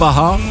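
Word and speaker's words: a-ha: Moi, je dis AHA. – Bah AHA, mais il y a un H. – Oui, mais il est muet.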